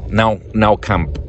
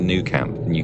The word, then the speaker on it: Nou Camp: Nou Camp, I believe is how you pronounce it in Catalan, but we called it the New Camp.